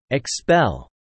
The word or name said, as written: expel